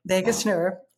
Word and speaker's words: vagus: But you mentioned something in your TED Talk about the vagus nerve. Am I saying this correctly? – Vagus nerve. Yes.